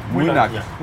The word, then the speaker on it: Mujnok: Nee, wie spricht man es aus? – Mujnok.